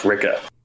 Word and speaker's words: Rikke: R-I-K-K-E. Rick. – Yeah. – I think technically her name is pronounced Ricka.